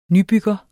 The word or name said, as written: nybygger